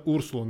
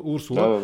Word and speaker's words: Урсула: И това са ме поправили немско говорящи мои приятели, че ударението е на Урсула, не на Урсула. Урсула.